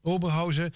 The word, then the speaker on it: Oberhausen: 2-1 gewonnen van Oberhausen, zeg ik dat goed? – Ja. – Ja, Oberhausen.